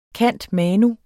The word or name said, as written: cand.manu.